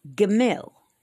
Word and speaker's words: Gimel: So without further ado, the first letter of the alphabet is Aleph, A-L-E-F. The second letter is Bet, B-E-T. The third is Gimel, G-I-M-E-L. And it's pronounced like goat, okay? The next is Dalet, D-A-L-E-T.